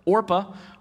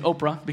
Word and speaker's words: Orpah: The wife of the other brother, Orpah, or half the time I say Oprah because it's spelled very similar.